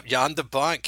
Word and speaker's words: Jan de Bont: Roland Emmerich, Tim Burton, Tony Scott, Chris Columbus, Ang Lee, David Fincher, Jan de Bont, is that how you pronounce his name? – Yep. Jan de Bont, cameraman to the film Roar, and, among other.